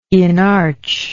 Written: inarch

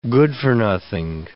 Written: good-for-nothing

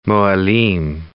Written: mohelim

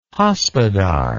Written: hospodar

